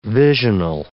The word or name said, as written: visional